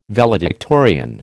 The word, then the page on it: valedictorian: American English